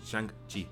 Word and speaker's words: Shang-Chi: Eh, que habíamos visto el tráiler, por ejemplo, de, Shang-Chi. ¿Lo dije bien? – Sí, Shang-Chi.